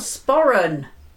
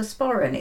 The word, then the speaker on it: sporran: A sporran. – Oh, is that how you say it? – Yes. – Oh. – It's a sporran.